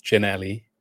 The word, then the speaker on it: Ginelly: Um, and Josh Ginley, or Ginelli, I think I said his name wrong the other day in a podcast I was on with Gabriel Sutton. Ginelli.